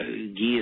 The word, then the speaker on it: ghee: I've noticed in a lot of recipes and things I've looked at, is it called ghee? Is that how you pronounce it? A form of butter. What's the difference between regular salted or unsalted butter and ghee? – Well, ghee is clarified butter.